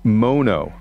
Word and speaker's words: Mono: It's pronounced Mono. – Mono. – Mono, not mono.